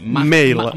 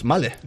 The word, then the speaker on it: MAHLE: Mail. – La pronunciación en alemán, ¿cuál es? Male. – Male. – Male. – No hay que poner cada enfadado. – Nada. Male. Al final un poco A.